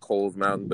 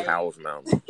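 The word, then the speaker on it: Cowles Mountain: And then I met some folks on I'll call it Cole's Mountain because it's properly pronounced Cole's Mountain, but you know what I mean? Yeah. I say Cow's Mountain. Everybody says Cow's Mountain. But the Rangers told us that it was Cole's, not Cow's. But anyway, so I met some folks and everything changed because then they started hiking.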